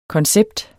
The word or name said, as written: koncept